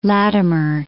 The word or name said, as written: Latimer